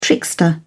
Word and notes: trickster: British English

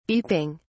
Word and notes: beeping: British English

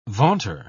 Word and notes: vaunter: British English

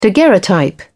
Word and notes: daguerreotype: British English

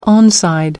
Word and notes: onside: American English

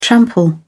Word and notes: trample: British English

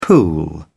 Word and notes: pool: British English

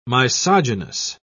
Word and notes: misogynous: British English

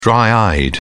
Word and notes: dry-eyed: British English

